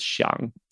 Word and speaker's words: Xiang: But previously, when I moved here from China, it was Xiang. So it's spelled X-I-A-N-G. – Oh, amazing. Yeah. – And obviously no one could pronounce it.